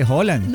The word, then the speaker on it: Haaland: Se pronuncia Haaland, o sea, como se dice.